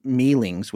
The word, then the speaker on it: Myling: And then if you go to Scandinavian lore, there's some mythology there regarding something called the Meelings, which maybe you've heard of, spelled M-Y, like Myling, but Meeling, I guess pronounced Meelings.